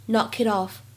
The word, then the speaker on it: knock it off: Knock it off, Jay. You don't know anyone's name here. 在这样一段话朗读过程当中呢，首先我们看一下开头的位置，knock it off 当中呢可以有两处连读的处理。细心的同学呢可能会发现当中呢还有一个美音浊化的现象，所以可以读成 knock it off。